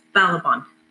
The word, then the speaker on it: Balaban: I may have been saying his last name wrong, so, but it is Balaban. B A L A B A N.